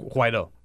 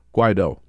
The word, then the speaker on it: Guaidó: But this Latin American strongman, Donald Trump, now has actually recognized the opposition leader, John Guaidó, is it? – Guaidó. – Guaidó. Yeah. Guaidó is how you pronounce Guaidó.